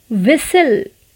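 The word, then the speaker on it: whistle: विसल विसल यहाँ भी T silent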